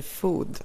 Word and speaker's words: food: Och min lärare uttalade det som food. Och då räckte jag upp min beskäftiga lilla hand och sa, fröken, jag tror att det heter food med långt o.